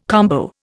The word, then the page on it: combo: Indian